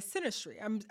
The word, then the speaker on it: synastry: So I'd love to transit, funny pun, into our last section of this episode, which is synastry.